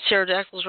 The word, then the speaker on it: pterodactyls: Because pterodactyls are extinct? – Besides that. – I don't know. – Me neither. – And I don't have any idea. – Because the P is silent. – Oh. – P-T-O.